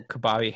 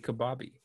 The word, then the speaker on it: kabaddi: I need to actually physically see it to remember. Kabaddi. Okay, I got it. Kabaddi.